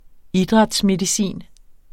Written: idrætsmedicin

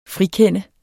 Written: frikende

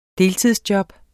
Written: deltidsjob

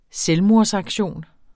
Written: selvmordsaktion

